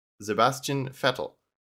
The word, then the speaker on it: Sebastian Vettel: Sebastian Vettel, German pronunciation, Sebastian Vettel, born 3 July 1987.